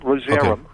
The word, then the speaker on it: Rozerem: It's spelled R-O-Z-A-R-E-M? – R-O-Z-E-R-E-M, Rosarum.